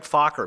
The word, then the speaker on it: Fokker: How do you pronounce it? Just like it's spelled. Fokker.